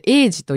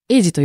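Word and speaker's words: 鋭児: さあ、まあ 2 曲目、今回曲紹介させていただくのが、えー、これ、鋭いと書い、鋭いと書いて児童の字。この 2 つの漢字で、エイジと読みます。エイジ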